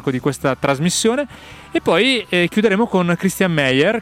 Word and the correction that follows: Christian Meyer: che Christian Mayer anzi eh, si pronuncia così,